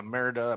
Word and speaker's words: Merida: Yeah, Merida, I believe is how it's pronounced.